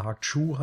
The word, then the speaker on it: Hark Tsui: Hark Tsui oder Hark Chu wird das, glaube ich, eigentlich ausgesprochen, ähm, und John Woo und so weiter.